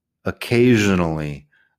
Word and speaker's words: occasionally: Rarely, occasionally. Occasionally tiene el sonido de j en medio con la S.